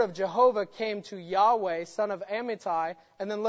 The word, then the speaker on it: Yahweh: Every verb, this one is, and it happened that the word of Yahweh or Jehovah, same name, I'll frequently say Yahweh, that's the Hebrew pronunciation.